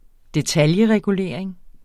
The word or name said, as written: detailregulering